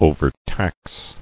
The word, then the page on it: Overtax: o·ver·tax